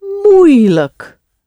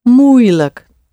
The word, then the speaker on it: moeilijk: So we have makkelijk and moeilijk. Moeilijk. Spelled M-O-E-I-L-I-J-K. Moeilijk. And moei is O-E, the O sound, and I. Yeah? Moeilijk. As ever, the like at the end is just pronounced look.